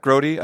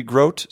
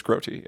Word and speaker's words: Grote: Yeah, Grotti. Actually, it's funny because, it's common. It's my opinion as an American, the way that it's spelled, it should be Grote. So when people say Justin Grote, like, I don't get offended. I'm like, yeah, because that's what it looks like it should be, right? But according to my parents, it's Grotti, yeah.